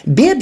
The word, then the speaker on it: bib: Bib, ça s'écrit B-I-B. Ça, c'est comme, c'est un, prénom non genré, mais ça peut s'apparenter à Bob, mais c'est bib.